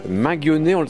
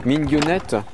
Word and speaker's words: Minguionnet: on est toujours à Gourin sur RCF, au manoir de Minguionnet. Ça, c'est la prononciation française, mais on peut dire aussi Minguionnette. – Alors, Minguionnette, mais il faut être breton pour bien le prononcer. Moi, j'en suis incapable, j'ai honte. Mais il le dit plutôt comme Minguionnette, mais je le dis mal.